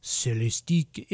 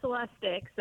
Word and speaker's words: Celestic: Joining us from Cadillac to talk about the Celestique. Is it, do you have to use a French accent when you pronounce Celestique? – No, it's actually Celestic, so no French accent needed.